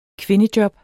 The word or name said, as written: kvindejob